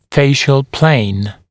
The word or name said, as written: facial plane